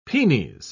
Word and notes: penes: British English

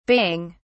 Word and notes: baying: British English

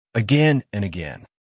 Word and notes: again and again: American English

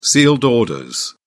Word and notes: sealed orders: British English

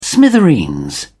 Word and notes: smithereens: British English

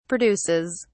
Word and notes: produces: British English

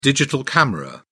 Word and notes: digital camera: British English

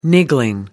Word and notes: niggling: British English

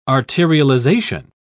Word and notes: arterialization: British English

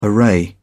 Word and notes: array: British English

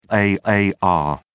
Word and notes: aar: British English

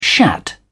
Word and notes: shat: British English

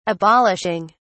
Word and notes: abolishing: British English

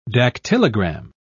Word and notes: dactylogram: British English

